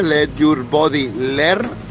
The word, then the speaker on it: Let Your Body Learn: Let Your Body Learn. Ahora lo he dicho bien.